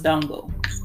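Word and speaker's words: Ndongo: It's N-D-O-N-G-O. Is the N silent? Is it... – Say it again. N-D... – O-N-G-O. – Yeah, the end is silent. – So it's Dongo.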